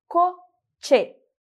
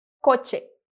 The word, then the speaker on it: coche: E por último, a palavra coche, que significa carro. Vamos dizer coche, coche.